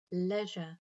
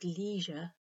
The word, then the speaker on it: leisure: Other common words with vowel differences are leisure, where the EI sounds like the E in leg in British English and the E in C in American English, so leisure and leisure, and vase, which in British English rhymes with the planet Mars, and in American rhymes with lace, so vase and vase.